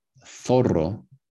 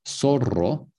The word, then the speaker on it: zorro: But a Z-A, Z-O, Z-U, is also, in Spain, th. Rizado. Zorro, zurdo. So it's kind of like a lisp. In Latin America, it's pronounced like an S.